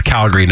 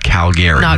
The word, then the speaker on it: Calgary: So, and if you're here for Stampede, by the way, it's Calgary, not Calgary.